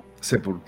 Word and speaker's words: sepultura: Yo le habría dado Santa Sepulcura. ¿Cómo se dice esa palabra? Sepultura. – Cállate, tonto, cállate, tonto, que no Sepultura.